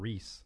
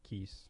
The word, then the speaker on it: Reese: That was Idol Worship with Talib Kweli and Reese, not Keese. – Reese. That's R-E-S. A chick. – Yep. – Talib Kweli. – In the last segment, I had said Keese or something. Yeah, said it wrong.